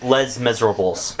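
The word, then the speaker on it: Les Misérables: Yeah, it's Les Miserables.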